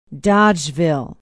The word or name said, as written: dodgeville